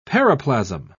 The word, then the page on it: paraplasm: British English